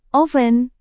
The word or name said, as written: oven